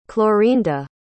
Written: clorinda

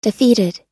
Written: defeated

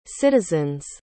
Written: citizen's

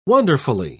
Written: wonderfully

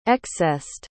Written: excessed

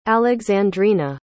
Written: alexandrina

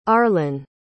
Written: arlyn

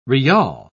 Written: riyal

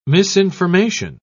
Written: misinformation

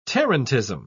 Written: tarantism

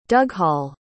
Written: dughall

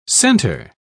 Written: center

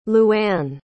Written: Louann

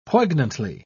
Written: poignantly

